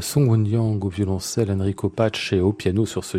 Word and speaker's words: Pace: Enrico Pace. Pace, c'est bien ça, parce qu'il est bien italien, donc le Pace.